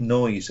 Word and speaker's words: Noyes: Last point on this, I've mentioned before when I was talking to you, a guy called Ralph Noyes,